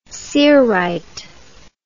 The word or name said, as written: cerite